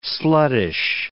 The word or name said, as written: sluttish